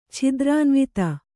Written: ಛಿದ್ರಾನ್ವಿತ

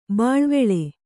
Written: ಬಳ್ವಳ

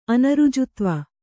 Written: ಅನೃಜುತ್ವ

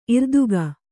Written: ಇರ್ದುಗ

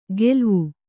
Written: ಗೆಲ್ವು

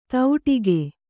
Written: ತೌಟಿಗೆ

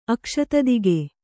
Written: ಅಕ್ಷತದಿಗೆ

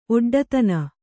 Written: ಒಡ್ಡತನ

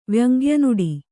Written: ವ್ಯಂಗ್ಯನುಡಿ

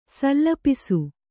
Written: ಸಲ್ಲಪಿಸು

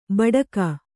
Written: ಭಾಡಿಗೆ

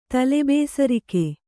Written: ತಲೆಬೇಸರಿಕೆ

